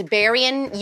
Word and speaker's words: Berean: If you like following Ali Stuckey or the Berean Millennial, I always say this wrong, it's Berean, you know who it is.